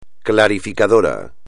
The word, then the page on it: clarificador: espagnol